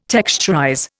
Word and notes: texturize: Indian

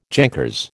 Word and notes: jankers: American English

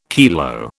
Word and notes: kilo: British English

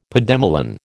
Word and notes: pademelon: American English

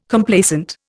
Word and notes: Complacent: Indian

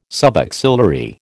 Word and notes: subaxillary: American English

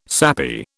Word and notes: sappy: British English

